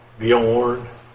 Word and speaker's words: Bjorn: Bjorn. – What's that? – It's a name. Bjorn. – Oh. – B-J-O-R-N.